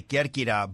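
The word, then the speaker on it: Kerkyra (Κέρκυρα): Oh, the Greeks say Kerkyra, but it's Corfu.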